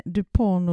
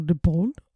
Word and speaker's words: Dupont: Sen Dupont och Dupont. Mm. Den ena stavar med D på slutet, den andra med T, men det uttalas likadant, stod det.